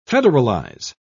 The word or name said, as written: federalize